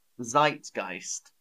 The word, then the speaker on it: zeitgeist: So my next word is zeitgeist. And again, we pronounce it more English than the language it's from.